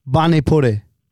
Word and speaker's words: pani puri: How do I say it then? – Like Bani. – Oh, is it Bani? – No, Bani. – Bani puri. – It's a P and a B at the same time.